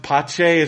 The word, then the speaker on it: Pache: And he's like the show fixer, and his name spelled out is Pizza, but it's pronounced Pache. It's just one of those dumb things, and like is really funny. – That's funny. – And so Pache takes like because he realizes that.